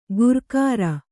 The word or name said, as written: ಗುರ್ಕಾರ